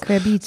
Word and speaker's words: Querbeet: Oder ich immer dachte, das wäre von Querbeat und da wurde mir gesagt, halt stopp, Querbeat heißt das gar nicht. Wie Querbeet hm. spricht man das nämlich ja, aus. Querbeet.